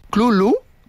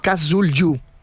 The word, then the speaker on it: Cthulhu: Por eso que la pronunciación más aceptada fonéticamente es Clulu, ¿vale? – Clulu. – Es que esto me ha encantado como digresión. Clulu o Cthulhu.